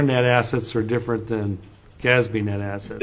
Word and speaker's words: GASB: It's GASB.